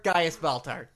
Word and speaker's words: Gaius Baltar: Are you sure you said that right? – Bias Galtar? Gaius Baltar.